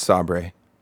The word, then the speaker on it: Saber: Well, it was announced this week that the developers, Saber Interactive, they're making a Quiet Place game. – I think it's pronounced Sabre. – Sabre, sorry.